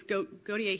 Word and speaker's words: Gautier: I'm saying that wrong. It's Gautier here. It's Gautier there.